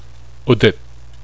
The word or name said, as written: Udit